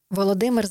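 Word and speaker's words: Volodymyr (Володимир): Volodymyr Zelensky.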